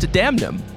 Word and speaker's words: Damnum: First of all, it's pronounced Damnum. You say the N.